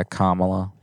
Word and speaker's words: Kamala: Is that how you say it? Kamala. – Yeah, Kamala. You could just say, I'm Miss Harris.